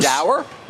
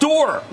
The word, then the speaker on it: dour: Dour? – No, and it's pronounced door.